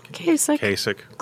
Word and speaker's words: Kasich: You had Kasich. Am I pronouncing his name right? – Kasich. Really close.